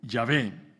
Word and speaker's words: Yahvé: las consonantes hebreas Y, H, V y H que tenéis aquí, que se pronuncia también Yahvé, y significa el que existe en sí mismo, o el que es lo que es sin fin.